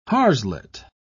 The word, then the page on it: harslet: British English